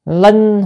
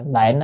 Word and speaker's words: linh: người ta dịch là lệnh á, cái chữ này linh mới đúng.